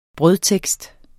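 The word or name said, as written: brødtekst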